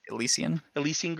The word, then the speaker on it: Elysian: I don't know how to say this. Illicin Grove? – Elysian Grove? That makes more sense.